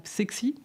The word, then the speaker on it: CECSY: Psexi, parce qu'on parle de symbiose. C'est le si de symbiose. – Oui, c'est ça, ça s'écrit C-E-C-S-Y.